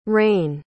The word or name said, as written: raine